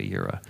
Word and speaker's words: Yireh: Jehovah Jireh, we pronounce it Jireh, but it's Yahweh Yireh.